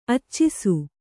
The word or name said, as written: ಅಚ್ಚಿಸು